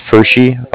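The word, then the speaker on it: Pferschy: It's Fershi. I'll spell it for you. It's P as in Paul, F as in Frank, E-R, S as in Sam, C-H-Y.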